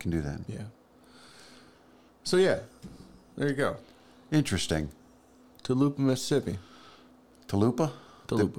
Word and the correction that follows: Tupelo: Did, did we just rename it? Uh, how do you say it? How do you say it? Tupelo. – Tupelo. Yeah. I don't know why I keep saying Tulupa.